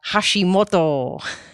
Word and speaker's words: Hashimoto: Thank you for coming on and talking about Hashimoto, Hashimoto's thyroiditis or disease. I just love saying that in my best Japanese accent. – It's the perfect pronunciation. It's the best one I've heard.